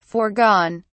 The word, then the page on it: forgone: British English